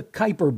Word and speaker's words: Kuiper: The part of the solar system where Pluto is located is the Kuiper Belt. It's spelled and pronounced like former major leaguer Dwayne Kuiper.